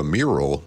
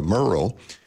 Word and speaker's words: Murrell: But the first person she thanked was the Attorney General, Liz Murrell, and she mispronounced her name. She turned her into a mural, not a Murrell.